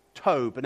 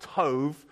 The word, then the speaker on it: Tov: Now, if I pronounced it Tov like that, it's because I'm trying to show off my Hebrew, and those of you who know your Hebrew well will know that the letter B in Hebrew can be pronounced B or V, and Tov, which is actually what you find written in the Hebrew scriptures, is the Hebrew word for good.